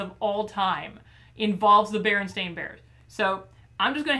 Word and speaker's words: Berenstain: Is it Berenstain or Berenstein? – Oh, we're going to get into that. Oh. That. There's a conspiracy theory. – I mean, it's spelled like stain. – It is. – But I don't call it that.